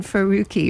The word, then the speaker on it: Faruqi: The name rhymes with kooky? – Yes, huh? I think so. Yes, Senator Maureen Faruqi is what we're talking about.